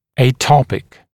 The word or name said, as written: atopic